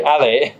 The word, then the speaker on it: alley: الی. – تلفظ ترکی علی یادت باشه. الی.